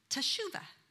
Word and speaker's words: teshuva: But the word is teshuva. It's pronounced other ways too, but that was the easiest one for me.